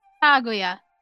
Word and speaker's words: Kaguya: Não, não tem tá sílaba tônica. Kaguya. – Kaguya. Tá bom. Então vou falar. – É tudo horrível, igual, né? Não tem sílaba tônica.